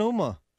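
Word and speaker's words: Huma: Yeah, silent Uma. Yeah, the H is silent.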